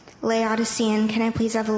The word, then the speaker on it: Laodicean: By the way, it's Laodicean.